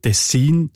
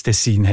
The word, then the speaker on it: Tessin: Tessin ist also näher am Original im Tessiner Dialekt und Tessin quasi die eindeutschte Variante.